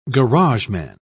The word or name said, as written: garageman